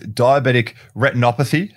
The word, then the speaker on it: retinopathy: Now, the most common, I believe, complication is diabetic retinopathy. How did my pronunciation go there, Peter? – That's perfect. Yeah. Well done.